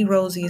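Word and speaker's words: Rosey: If you haven't followed me on Instagram, feel free to do so at I am Rosie the Boss Lady. Rosie is spelled with EY.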